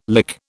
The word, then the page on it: lick: British English